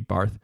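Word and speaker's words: Barthes: It's spelled like Barts, but the pronunciation would be Barth, and because he's French,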